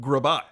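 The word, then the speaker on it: Grbac: It's pronounced Grabak.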